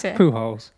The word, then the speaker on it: Pujols: Pujols? How do you say it? – Pujols. – Pujols. Oh, I knew I'd probably say it wrong.